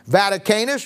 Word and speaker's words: Vaticanus: Vaticanus, V-A-T-I-C-A-N-U-S.